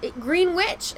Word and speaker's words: Greenwich: Do you really think you pronounce that W? – Yeah. Greenwich.